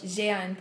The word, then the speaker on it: Jehanne: Jehanne Poirier.